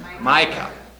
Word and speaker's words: Micah: It's one sentence out of the prophet Micah, you call him, or Micah? Micah. In German it's Micha, so I have to change every time the names.